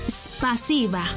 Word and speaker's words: спасибо: En ruso, gracias se dice spasiba. ¿Cómo? Spasiba. Una vez más. Spasiba. Y se escribe spasiba. S-P-A-S-I-B-A.